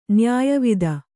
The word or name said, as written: ನ್ಯಾಯವಾದಿ